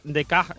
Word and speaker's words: Descartes: Porque como te he dicho, Descartes se pronuncia Descartes en francés, ¿vale?